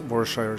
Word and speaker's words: Worcestershire: Det er Worcestershire sauce. – Står det på flaska, men jeg mener at det skal uttales Warshire sauce.